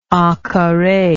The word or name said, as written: encarre